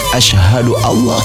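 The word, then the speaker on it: asyhadu alla (أشهد أن لا): Idgham bila guna itu dibaca langsung, tidak perlu didengungkan, sehingga bacanya adalah asyhadu alla ilaha illallah. Jadi bukan asyhadu Allah.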